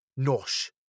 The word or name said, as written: nosh